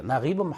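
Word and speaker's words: 나깁 (نجيب): 이렇게 연결형이기 때문에 이렇게 격표시 사람들이 하기 힘들어하는데 카타바 나지브 마후딘 이집트식으로 발음하면 나깁이에요. 나깁 마후딘 리와야탄 나지브 마후드는 소설을 썼습니다.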